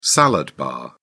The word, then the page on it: salad bar: British English